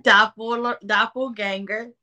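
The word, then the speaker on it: Doppelgänger: How do you pronounce it? – Doppelganger. – Doppelganger effect.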